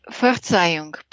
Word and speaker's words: Verzeihung: La règle suivante est Verzeihung, pardon. Prononcez le Z bien dur ts, et non pas z.